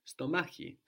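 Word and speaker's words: stomachi (στομάχι): So this X letter, this K sound, is pronounced in two slightly different ways. It's kind of soft when it has an E or an I after it, stomachi.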